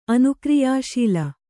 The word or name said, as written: ಅನುಕ್ರಿಯಾಶೀಲ